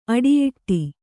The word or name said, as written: ಅಡಿಯೂಡು